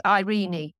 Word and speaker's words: Irene: Irene Barclay, Irene. It's actually Irene. It's pronounced.